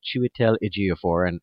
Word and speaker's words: Chiwetel Ejiofor: Now let's take a moment to talk about Chiwetel Ejiofor, and for me to be proud that I think I probably said that correctly. – You did say it correctly.